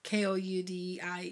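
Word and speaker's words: Cutillero: The family that she was going to be working for was Sabrina Coutier. I might be saying that wrong. Coutier. – And this is in London? – Is it C-O-U-T-I-E-R? – No, it's Kudiero.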